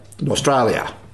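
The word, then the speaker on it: Norstrilia: Nostralia. Got to get that right. Nostralia. – Well, I'm sure that Cordwainer Smith would have pronounced it that way.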